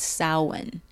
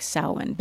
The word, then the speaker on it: Samhain: And it was actually called Samhain. Back then, by the Celtic Samhain, but spelled like Sam Hain.